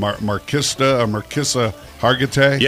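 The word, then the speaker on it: Mariska Hargitay: A mother to, how do you say her name? Marquisa Hargitay? – Yes. – The actress. – Close enough.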